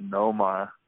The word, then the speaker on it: Nomar: Just a quick, I have an honorable mention just off the Nomar, or as we pronounce Noma.